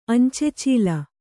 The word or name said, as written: ಅಂಚೆಚೀಲ